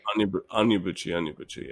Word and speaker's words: Onyebuchi: Toki, T-O-T-H-I, Toki. – Probably Toki Onyebuchi. – Onyebuchi. – Would be my guess. – Toki Onyebuchi. – Onyebuchi, yes.